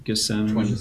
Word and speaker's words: Gethsemane: Gethsemane.